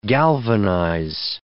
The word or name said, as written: galvanize